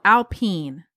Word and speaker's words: Alpine: It's French. Yep. – Mm-hmm. It's called Alpine. – Yep. Yep. Really hit the emphasis on the second syllable.